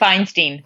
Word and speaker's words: Beinstein: Yeah, Beinstein.